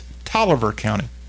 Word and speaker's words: Taliaferro: But the county of which his hometown of Crawfordville, Georgia, is located is pronounced, pretty darn sure I'm right on this, the pronunciation of Taliaferro County is not that, it's Tolliver County. Almost like Bolivar, but with a T.